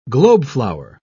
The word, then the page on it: globeflower: British English